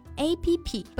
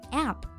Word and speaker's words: app: 但是关于这个手机应用啊，很多人也会读成 A P P，这个读法也是错的啊，其实。 – Right, many people pronounce it as app, but the correct pronunciation is app as in apple.